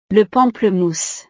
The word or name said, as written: le pamplemousse